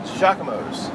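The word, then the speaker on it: Jacques-Imo's: It's spelled Jacques Emos, but it's pronounced Giacomo's.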